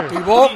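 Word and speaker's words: Thibaut: Tibi. ¿Cómo se dice? – Tibú Courtois.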